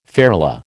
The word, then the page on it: ferula: American English